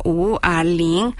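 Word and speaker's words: Wu A Ling (五二零): Por eso se escribe 520 y se pronuncia Wu A Ling, un sonido muy similar a Wo Ai Ni, que significa te amo.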